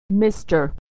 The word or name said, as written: mister